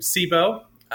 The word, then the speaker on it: Cibo: We also have a dog named Sibo, which it's spelled C-I-B-O, and if you look that up a little bit, it's Italian, and if you translate it, it means food, which we didn't know at the time, but we thought it was a cool name.